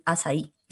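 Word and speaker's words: açaí: Y ahora en los desayunos se usan pudín de azaí. Se escribe acaí.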